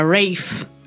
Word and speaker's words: Ralph: E dicono che si pronuncia Rafe.